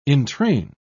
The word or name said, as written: entrain